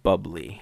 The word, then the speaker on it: Bublé: It's pronounced bubbly.